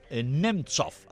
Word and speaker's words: Nemtsov: pues del opositor Boris Nemtsov, así se pronuncia, pues todo parece indicar que no está esclarecido y la situación se complica para el presidente, precisamente Vladimir Putin.